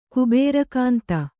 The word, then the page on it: ಕುಬೇರಕಾಂತ: Kannada